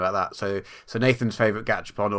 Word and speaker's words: Gachapon: Gachapon? – Gachapon.